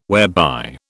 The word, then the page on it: whereby: British English